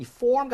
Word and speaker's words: form: 자, 중요한 건이 form 같은 경우에 R이 살아있기 때문에 R을 무시하고 그냥 form 하면 이건 땡이야, 땡.